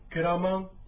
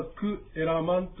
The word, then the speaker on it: ケラマン: これはタルガー流域ですから、ケラマンというふうに発音いたします。これはもともとはクエラマンということが、タルガー流域ではくっつきまして、ケラマンというふうに発音します。